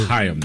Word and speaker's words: Chaim: It's pronounced Chaim, though, I think.